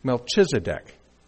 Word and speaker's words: Melchizedek: specifically Melchizedek.